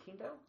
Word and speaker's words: Quinto: Wait, have I been saying it wrong? Is it Quinto? – Quinto. It's, it's Spanish.